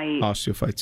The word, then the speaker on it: osteophytes: Ossites okay. – in my osteophytes.